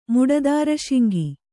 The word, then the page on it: ಮುಡದಾರಸಿಂಗ: muḍadāra singa